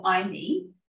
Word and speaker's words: iMi: It's called iMe. It's spelled I-M-I, and it's about iMe.